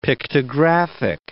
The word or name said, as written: pictographic